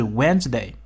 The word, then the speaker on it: Wednesday: 不过要注意他们的拼写，尤其是 Wednesday，同学们特别容易把字母 D 给漏掉了，因为 D 呢是一个不发音的哑音词。